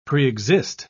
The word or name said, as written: preexist